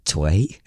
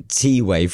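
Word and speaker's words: T'way: So I was like, is it Tway? Is it? But Tiwei, from our understanding, we are correct by pronouncing it, are going to take 777s.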